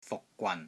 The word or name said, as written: FUK6 GWAN3